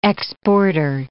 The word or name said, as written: exporter